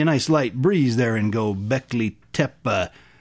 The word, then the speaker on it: Göbekli Tepe: Göbekli Tepe. Did I say that right? – Yes, actually. – Göbekli Tepe.